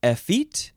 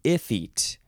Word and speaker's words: effete: You can say effete or effete. It is spelled E-F-F-E-T-E.